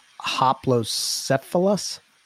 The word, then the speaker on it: Hoplocephalus: but I know I'm probably going to butcher this, but Hoplocephalus. – Perfect, I love it.